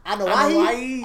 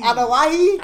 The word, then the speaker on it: Anoa'i: The Ano Anoa'i Anoa'i Anoa'i.